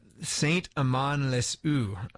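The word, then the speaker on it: Saint-Amand-les-Eaux: Today, the town is literally named St. Amand-les-Eaux. Did I say that right? – You did.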